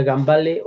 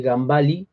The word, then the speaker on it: Gambale: No sé si se pronuncia Gambale o Gambali, creo que se pronuncia correctamente Gambali, porque su apellido es de origen italiano,